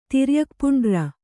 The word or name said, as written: ತಿರ್ಯಕ್ಪುಂಡ್ರ